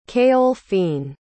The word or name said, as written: caoilfhinnn